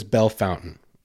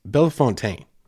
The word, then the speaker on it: Belle Fontaine: Everyone says Belle Fountain. It's Belle Fontaine. Like, there's not even a U in there, but everyone just says Belle Fountain.